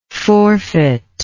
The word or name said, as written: forfeiter